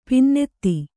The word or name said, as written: ಪನ್ನತಿ